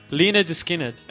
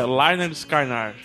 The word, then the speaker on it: Lynyrd Skynyrd: É a pronúncia correta. Eu falava Lynyrd. – É Lynyrd? – Lynyrd Skynyrd. – Sério? – É. Eles mesmo, eu vi entrevista, do Lynyrd Skynyrd. Eu pronunciava Lynyrd Skynyrd no começo.